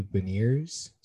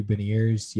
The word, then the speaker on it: Beniers: I think on TV they pronounce his name Matthew Beniers, so I think I'm gonna go with that. So it sort of looks like a French name, but it's like pronounced in like an English way. So Matthew Beniers, yeah, he looks really solid.